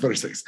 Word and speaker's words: Versace: It's pronounced Versace.